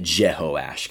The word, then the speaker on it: Jehoash: It's really Jehoash, kind of that's how you say his name.